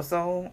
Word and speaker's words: Seoul: You want to pronounce this one in South Korea? – Seoul, South Korea. – Oh, Seoul. Oh, that's a hell of a... The E is silent.